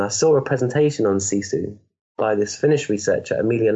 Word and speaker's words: sisu: Sisu, yeah.